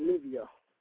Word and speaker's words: Nivea: Is that how you say it, or is it Nivea? Because I've heard it pronounced both ways. – I think she pronounced it Nivea.